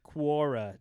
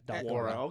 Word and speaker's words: Quora: How do you spell that? – Yeah, that sounds legit. – Q-U-O-R-A. Quora. – Quora. Okay, just the way it sounds.